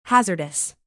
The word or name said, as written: hazardous